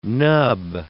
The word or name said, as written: nub